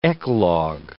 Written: eclogue

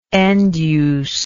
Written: end use

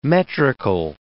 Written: metrical

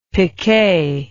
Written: picquet

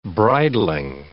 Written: bridling

